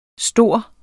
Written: stor

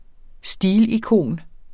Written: stilikon

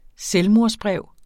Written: selvmordsbrev

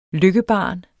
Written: LYKKEBARNET

